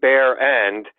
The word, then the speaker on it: Barron: Yeah, most people get it wrong, but it's a German name, and it is actually pronounced Bear End, and it means naked ass.